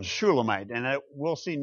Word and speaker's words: Shulamite: The vowels, however, make us pronounce it Shulamite instead of Solomon.